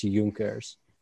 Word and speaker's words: Junkers: Well, that's easy to pronounce when you realize that Germans say their J's as Y. So just pronounce it as Junkers instead of Junkers. So I used to call it Junkers too, but it's Junkers.